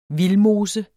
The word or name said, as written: vildmose